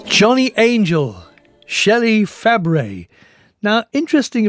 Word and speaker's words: Fabray: In fact, her aunt was the actress Nanette Fabre, who officially changed the spelling of her own last name to F-A-B-R-A-Y, so people would pronounce it correctly, Fabre.